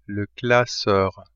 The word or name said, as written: classeur